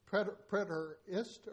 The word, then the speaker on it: Preterist: I will spell it. You tell me. P-R-E-T-E-R-I-S-T. Preterist or iced? – Preterist. – Preterist. Who said that?